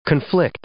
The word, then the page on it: conflict: noun